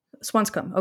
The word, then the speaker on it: Swanscombe: The Ashsmith family apparently resided in Swanscombe. Is that how you pronounce it? – Swanscombe. – Okay. – Yeah, so pretty much the way you did it.